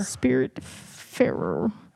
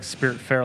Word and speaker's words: Spiritfarer: Spiritfarer. – Farer. – Farer. – Spell that. – What? – S-P-I-R-I-T-F-A-R-E-R. – Oh, okay. – Spirit fair. – I thought it was like spirit fair, like F-A-I-R-E.